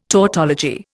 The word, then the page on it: tautology: Indian